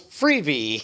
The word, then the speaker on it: Freevee: It's a smart time to rebrand from IMDb TV to two syllables, Freevee. – Freevee.